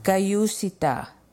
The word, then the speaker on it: Kayusita: Kayusita means let us move forward, and I want to teach you how to pronounce it. Kayusita. Kayusita. Kayusita. Let us move forward.